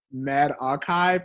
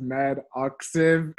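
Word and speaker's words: medRxiv: This is probably super unrelated, but there was a tweet I saw about how people have been mispronouncing Med Archive as Med Oxiv.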